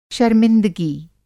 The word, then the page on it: शरमिंदगी: شرمندگی